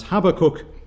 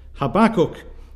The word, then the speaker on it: Habakkuk: with emphasis on the first syllable as Habakkuk. Whereas, of course, if we simply take the time to look at the self-pronouncing text of our authorized version, it is clear that the emphasis is supposed to be upon the second syllable, and this man is called Habakkuk,